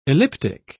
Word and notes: elliptic: British English

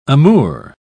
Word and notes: amour: British English

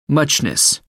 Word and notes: muchness: American English

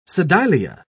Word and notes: sedilia: British English

